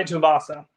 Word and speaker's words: Tuivasa: Tai Tuivasa.